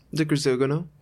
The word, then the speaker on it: de Grisogono: In 2019, De Grisogonio, did I say that right? – De Grisogono.